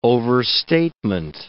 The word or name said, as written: overstatement